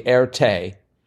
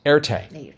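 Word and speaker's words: Erté: His acquired name is actually the French pronunciation of the R and T, roughly Erte, from his given name, which, as the crossword pointed out, is Romain de Tirtoff. So that's how you pronounce it.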